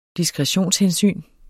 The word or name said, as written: diskretionshensyn